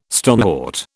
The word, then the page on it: stonewort: British English